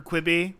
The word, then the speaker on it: Quibi: So would Quibi still be with us to get today if they had if it'd been clear that it was supposed to rhyme with ribeye?